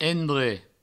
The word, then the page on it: endrech: endre